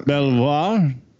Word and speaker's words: Belvoir: And of course, on his way there, he couldn't resist heading to, how do you say that? Bolivar? Bolivar? – Belvoir.